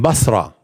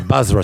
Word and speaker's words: Basra: We were with Bukhari in Basra, which is where? Iraq, ahsant. They pronounce as Basra today. Basra.